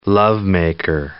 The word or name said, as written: lovemaker